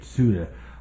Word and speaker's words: Ceuta: The migrants swarm into Ceuta, that's how you say it, Ceuta, a Spanish coastal enclave in North Africa, by land and sea.